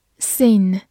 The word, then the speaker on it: scene: Scene, S C E N E, scene, S C 发 s 中间的字母 e 发它本身的音 e，n 发嗯，末尾的 e 不发音。